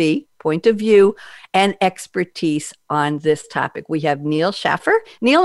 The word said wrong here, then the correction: Schaffer: It's Schaefer, but that's okay. – Schaefer, I want to make sure. I should have asked you before the show. Neil Schaefer, S-C-H-A-F-F-E-R.